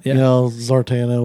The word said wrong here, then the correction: Zatanna: whatever it is. – Zatanna. – Zatanna, yeah. I always say her name wrong, but I like the character.